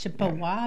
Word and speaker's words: Chippewa: Chippewa. – Germany, Wisconsin. – It's Chippewa Falls. Chippewa Falls. – Chippewa.